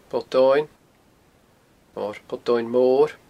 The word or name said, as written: Poll Domhainn